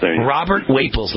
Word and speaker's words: Wapples: Wapples, got it. Robert Wapples, like Staples Superstore. Yep.